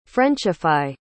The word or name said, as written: frenchify